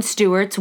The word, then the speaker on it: Steuart: On another occasion, John Stewart's wife was in the major's old study. I know. It's spelled S-T-E-U-A-R-T. – It's gotta be Stewart.